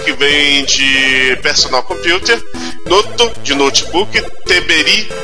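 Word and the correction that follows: Terebi (テレビ): de televisão, Não, etc. Terebi. Terebi. Terebi.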